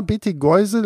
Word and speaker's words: Betelgeuse: Und ja, Betelgeuse, letztendlich wie der merkwürdige Name von Beetlejuice im Film. Nur geht es hier halt um Betelgeuse, den roten Überstern, den roten Riesen im Sternbild von Orion.